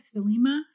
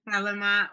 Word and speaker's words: Thelema: Is that Thelema, how you pronounce it? – Thelema or Thelema. I don't know. I've heard people say it both ways.